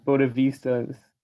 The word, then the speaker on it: bodhisattva: of like bodhisattvas. Let me pronounce this correctly. Bodhisattva. Yes. Yeah.